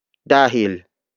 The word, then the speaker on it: dahil: Dahil is spelled as D-A-H-I-L.